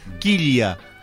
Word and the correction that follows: Quilla: Quilla. – Pero pronuncia así, claro. – Ahora sí. – No, porque la doble claro. L se pronuncia ya.